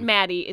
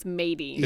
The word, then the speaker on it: Madie: Yeah, it's not Maddie, it's Mady. – It's spelled M-A-D-I-E, but it's pronounced like she's a fucking pirate.